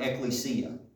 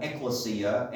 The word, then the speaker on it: ekklesia: I have always called it ekklesia. And in doing a bunch of digging over the last two or three weeks, I realized that it's supposed to be pronounced ekklesia.